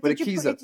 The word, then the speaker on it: Akeso: Is that how you say it? I was calling it a queso at first. I was like, sounds like cheese. – But Akeso. Did you have a little sharp sign over there? – I didn't see that.